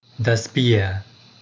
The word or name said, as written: das Bier